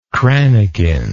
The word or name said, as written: crannequin